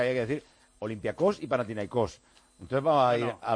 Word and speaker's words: Panathinaikos: Pero se dice Panathinaikos, os lo digo en serio.